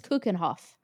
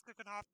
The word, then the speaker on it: Keukenhof: I think it's Keukenhof. – Keukenhof Gardens? – Yeah, it's Keukenhof.